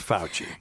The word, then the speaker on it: Fauci: Fauci, that's right. – Okay, well, I heard people say it different. – Well, so. It's Fauci.